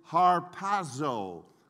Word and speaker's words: harpazo: Now, the Greek verb which is translated caught up is harpazo. In English, we would say it's spelled H-A-R-P, as in Peter, A-Z-O. So the Greek word is harpazo.